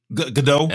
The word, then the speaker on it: Gadot: Is that how you pronounce her last name? – Godot. – Just making sure. – Godot. Okay, cool.